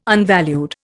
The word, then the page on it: unvalued: Indian